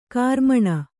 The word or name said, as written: ಕಾರ್ಮಣ